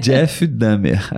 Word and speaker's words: Jeff Dahmer: Nos Estados Unidos que é Jeff Dahmer. – Isso aí. Falei certo Nossa. o nome? Acho que sim, eu falaria assim também. Jeff Dahmer, acho que é isso.